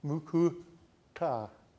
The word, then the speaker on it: mukuta: You see that dot under the T? Nara Mukuta? The T has a dot under it. What that means is you pronounce it with your tongue turned backwards and upside down in your mouth, mukuta, like that. If you pronounce it with your tongue right side up and forwards, it's wrong. It means something else.